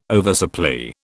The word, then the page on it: oversupply: British English